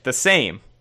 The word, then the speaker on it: Sejm: Their parliament, the Sejm, that you like a lot, has to be pronounced quite similar to the word same. Oh, what? – Oh. – Okay, well. – We're going to continue to call it Sejm, though.